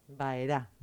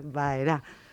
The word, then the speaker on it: Baerá: vamos a la Baerá. – Baerá. – Con bueno, acento en la A. – Sí, señor, Baerá.